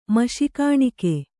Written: ಮಶಿಕಾಣಿಕೆ

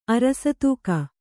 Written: ಅರಸತೂಕ

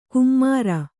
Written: ಕುಮ್ಮರ

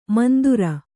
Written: ಮಂದುರ

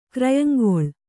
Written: ಕ್ರಯಂಗೊಳ್

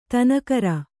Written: ದಿನಕರ

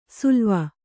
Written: ಸಳವ

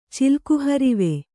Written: ಚಿಲ್ಕುಹರಿವೆ

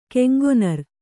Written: ಕೆಂಗೊನರ್

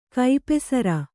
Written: ಕೈಪೆಸರ

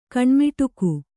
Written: ಕಣ್ಮಿಡುಕ

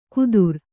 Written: ಕುದುರ್

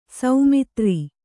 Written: ಸಮುದ್ರ

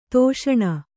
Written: ಧಿಷಣೆ

